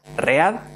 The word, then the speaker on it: read: Uno escribe R-E-D y otro read, red. Fijaros. Y aquí entramos en el triple problema porque se escribe igual que lo que es el presente del el infinitivo del verbo leer, read, pero se pronuncia distinto, read, read.